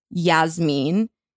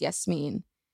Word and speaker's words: Yasmine: You know, I used to pronounce my name as Yasmin. That's not my name. How embarrassing is that? Literally till college, I was like, my name is Yasmeen.